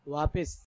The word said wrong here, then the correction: واپس: آپ نے جواب دیا واپس. اس طرح آپ کا جواب ہو گیا غلط. صحیح تلفظ ہے واپس.